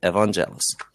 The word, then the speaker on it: Evangelos: That's exactly how you say it, Evangelos. You said it correctly.